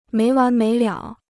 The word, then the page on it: 没完没了: méi wán méi liǎo